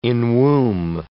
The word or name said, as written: enwomb